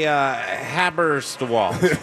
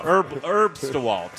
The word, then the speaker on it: Herbstwald: I was wondering how you pronounce that. Let me see what I would have said. – Sure. – It looks like a... that's a Haberstwald. – There you go. Close enough. – Herbstwald.